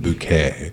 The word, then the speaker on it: Bucket: this like she plays this like woman who tries to be super fancy, and so her married last name is Bucket, but she pronounces it Bouquet.